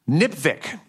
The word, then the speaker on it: NPVIC: A way to pronounce NPVIC, NPVIC, which it rhymes with lipstick.